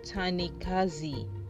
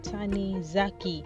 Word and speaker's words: Tanizaki: It is Jun Ichiro Tanikazi. Yeah? Tanizaki.